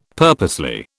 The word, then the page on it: purposely: British English